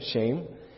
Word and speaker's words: Shem: We say it's Shem, but it's spelled the exact same, shame.